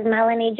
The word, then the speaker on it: Mellanie: I have my personal Facebook as Mellanie Joy.